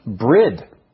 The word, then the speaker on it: bryd: Centuries ago, the old English word bird was spelled B-R-Y-D and pronounced brid, while wasp was spelled W-A-P-S and pronounced wops.